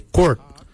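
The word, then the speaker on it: goord: For heart, we say quart. Your quart is your heart.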